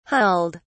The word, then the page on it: hulled: British English